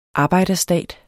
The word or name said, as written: arbejderstat